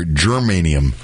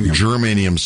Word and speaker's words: germanium: Well, this is this material that's gone missing is called the geranium. I'll make sure I'm pronouncing that correct, because geranium is a flower. Germanium. – Germanium 68.